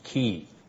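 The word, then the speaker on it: key (כִּי): the kaf succeeded by an accented yod, pronounced key.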